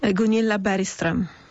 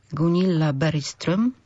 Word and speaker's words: Gunilla Bergström: Gunilla Bergström. – Gunilla Bergström.